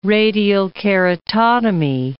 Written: radial keratotomy

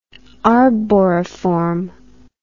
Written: arboriform

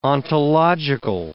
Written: ontological

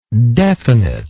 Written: definiteness

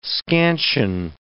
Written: scansion

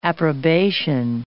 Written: approbation

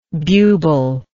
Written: bubal

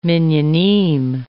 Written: minyanim

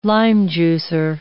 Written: lime-juicer

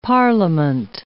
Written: parliament

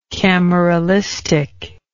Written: cameralistic

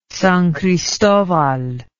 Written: sancristobal

